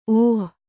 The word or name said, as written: ಓಹಿ